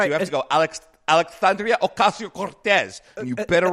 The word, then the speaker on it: Alexandria Ocasio-Cortez: Alexandria Cortez or something? – No. You said it in a way that makes you sound real racist. You have to say it like this.